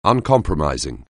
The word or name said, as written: uncompromising